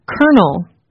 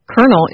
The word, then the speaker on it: colonel: Kernel is spelled C-O-L-O-N-E-L. So why do Americans say kernel as if it is spelled K-E-R-N-E-L?